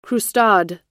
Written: croustade

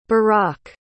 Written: berach

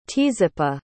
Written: tzippa

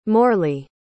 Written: morley

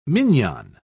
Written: mignon

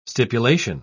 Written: stipulation

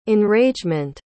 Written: enragement

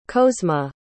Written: kozma

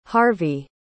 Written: harvey

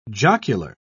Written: jocular

